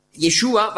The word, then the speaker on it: Yeshua: Yeshua war ein Problem für die Griechen, denn im griechischen Alphabet gibt es keinen Sch-Laut, sondern es gibt nur das Sigma, S. Also konnte man nicht sagen Yeshua, sondern höchstens Yeshua.